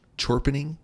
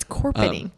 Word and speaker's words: Chorpening: Because that's how his name is spelled, but it's pronounced Corpening. – It's Corpening.